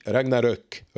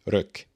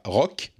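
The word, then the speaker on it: Ragnarök: S'il sort aussi God of War Ragnarok, d'ailleurs, ça se lit Ragnarok, Ruck, pas Rock, mais Ruck, si vous voulez prononcer comme il faut.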